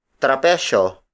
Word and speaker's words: trapecio: However, according to the Spanish Royal Academy, trapezoid is translated as trapecio. Trapecio. Trapecio. Which in Tagalog is spelled as T-R-A-P-E-S-Y-O.